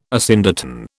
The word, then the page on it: asyndeton: British English